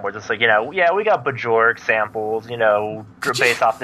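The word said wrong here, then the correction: Björk: Did you just say Bajoric? – Bajoric? – I think we just had the funniest mispronunciation. Rhett, you have been dethroned. Because guess what? Segu is not the funniest anymore. Because we now have the distinguished honor of having the funniest mispronunciation on this podcast with Bajork. – I'm hanging up now. – Her name's Bjork, you dip.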